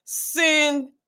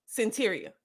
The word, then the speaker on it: Cyntiria: The pronunciation of the name is Cyntiria. – Cyntiria.